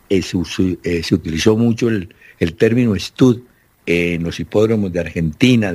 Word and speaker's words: stud: Es una palabra en inglés que se escribe stud, S-T-U-D, stud.